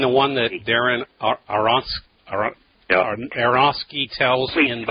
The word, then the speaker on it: Aronofsky: Aronofsky, that's how you say it.